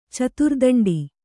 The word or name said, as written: ಚತುರ್ದಂಡಿ